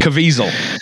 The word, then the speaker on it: Caviezel: Caviezel. – Caviezel.